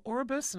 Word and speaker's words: Orbis: In addition to the Flying Eye Hospital, Orbis, and I'll spell that O-R-B-I-S,